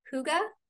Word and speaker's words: Hygge: Okay, you guys, I am so stoked to have Jamie here to talk about Hygge. Am I saying that right? – You are. Well done. It's not an easy word.